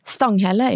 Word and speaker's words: Stanghelle: Ja, nej, men jeg, jeg læste den, og jeg, Harald, heter han, uttalas det Stanghelle? Stanghelle, ja. Stanghelle, ja men det är en journalist som jag träffat och tycker om.